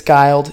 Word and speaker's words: Guild: And actually recently, I did tell him this, recently my Siri on my phone pronounced it Kevin Guild. So it's Guild, he corrects me.